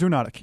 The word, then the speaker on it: zoonotic: It's, it's, it's How do you say endemic. the word zoonotic? zoonotic Zonitic uh, disease? zoonotic, yeah. Right.